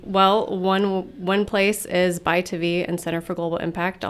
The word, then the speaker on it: ByTavi: Buy to V is spelled B-Y-T-A-V-I.